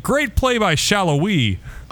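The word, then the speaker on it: Salloi: talking about when Tutino says Shallowy. Out of nowhere, after saying it wrong a couple times in the second half, even to that point, he says, Daniel Shallowy, and I'm like, whoa!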